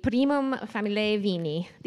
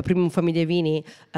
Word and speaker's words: Primum Familiae Vini: it's part of the Primum Familiae Vini, and you're the 10th oldest wine family in the world. – Yes, and I personally know the our friends of the Primum Familiae Vini, all the wineries, very well, because I've been the president twice.